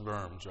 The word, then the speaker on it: Worms: It's spelled like Worms, but it's pronounced Worms, right, Bill?